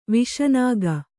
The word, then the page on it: ವಿಷನಾಗ: viṣa nāga